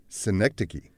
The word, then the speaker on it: Synecdoche: Synecdoche. A part is made of represented... – Synecdoche.